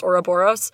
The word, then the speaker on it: Ouroboros: it's a word that I used to say in my head as Ouroboros, like when I read it in books, so it's really hard for me to reconcile the fact that it's actually pronounced Ouroboros, so I sometimes will say it wrong anyway.